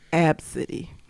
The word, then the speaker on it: Abcde: Oh, so the child's her name is spelled A B C D E, and the mother pronounces it Absidy.